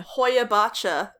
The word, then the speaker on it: Hoiabaciu: It's spelled H-O-I-A-B-A-C-I-U. So, you know. – No, I have no idea. – Hoyabacha is how I heard them pronounce it, so Hoyabacha.